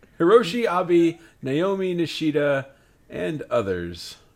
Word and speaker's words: Naomi: Because, yeah, it's Naomi, but in Japanese it's like Nomi.